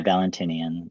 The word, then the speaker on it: Valentinian: So I wanted to ask you about Valentinian. Am I saying that right? Valentinian Christianity? – Yeah, Valentinian.